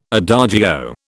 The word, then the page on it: adagio: British English